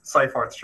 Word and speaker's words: Seyfarth: Did I say that right? Sayforth or Seaforth? Yes, Sayforth Shaw. Seyfarth.